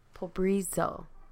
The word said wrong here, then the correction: Pobreza: That's an A. – We keep moving it. Pobreza. – So I was right.